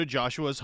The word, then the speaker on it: Joshua: Have you seen Dakota Joshua's Hockey Reference pronunciation guide, by the way? – It? – It's like Dakota. Yeah. And then it's Josh, spelled J-A-W-S-H, and then O-O-O-A. Joshua.